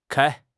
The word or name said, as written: kai1